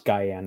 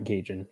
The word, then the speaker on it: Gajan: He's talking about going back to Adam Guyan, and I believe it's pronounced Guyan, not Gajan, just FYI.